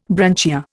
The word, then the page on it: branchia: Indian